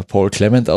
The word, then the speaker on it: Clement: Also was man so gehört hat, war, ich hätte ihn jetzt ja Paul Clement ausgesprochen als Engländer, aber ja. – Aber ist auch richtig so.